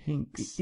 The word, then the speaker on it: Hinx: But, so this so they're looking for someone called Hinks, H-I-N-X. Is that how you'd pronounce that? – I don't like the idea. But Hinks.